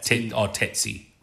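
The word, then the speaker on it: tsetse: Yeah, other ones included testy flies, I think. How do you say that? – Tetsy. – Tetsy. Tetsy right.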